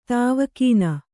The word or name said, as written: ದವಾಖಾನೆ